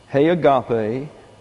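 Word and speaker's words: he agape (ἡ ἀγάπη): Now, this is that little breathing thing there is a rough breathing. Therefore, you translate that as an H. And this is an eta. It's pronounced like an English A, hey, but it's an E. So it's H-E. And this is agape, A-G-A-P-E, he agape, tu, T-O-U, theou.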